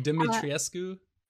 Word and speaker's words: Dimitrescu: Dimitrescu? – I think you don't actually pronounce like the U at the end, which is what trips people up.